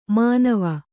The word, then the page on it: ಮನವೆ: Kannada